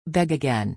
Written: beg again

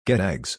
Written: get eggs

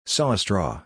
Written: saw a straw

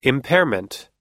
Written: impairment

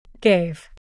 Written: gave

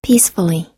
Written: peacefully

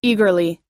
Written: eagerly

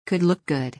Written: could look good

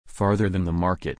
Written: farther than the market

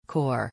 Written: core

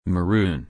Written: maroon

